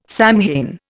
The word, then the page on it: Samhain: Indian